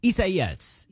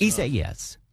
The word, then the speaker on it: Isaias: Hurricane Isaias, I think, is how you properly pronounce that. Let's check the Metro News meteorologist, Brian Hughes. Am I saying it right? Close enough? – Yes. Yeah, close enough. Isaias. Yes. All right. That is correct.